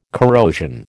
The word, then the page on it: corrosion: American English